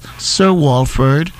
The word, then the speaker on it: Sir Walford: Email me or call me on the station at 442-4242, or you could email me as spell as Sir Walford.